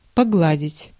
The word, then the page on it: погладить: /pɐˈɡladʲɪtʲ/; pogladit